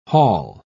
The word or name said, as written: haul